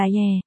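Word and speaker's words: Yè (叶): Bạch Diệp, giả của ban đêm, chữ Diệp đọc là Yè, mà chữ giả cũng là Yè.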